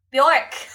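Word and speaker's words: Björk: Is that how you pronounce it? Bjork.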